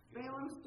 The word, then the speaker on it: Balaam: you know, and it's Balaam's, am I saying that right? – Yeah. – Balaam's donkey.